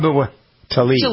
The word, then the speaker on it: Tlaib: Tlaib.